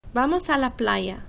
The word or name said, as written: vamos a la playa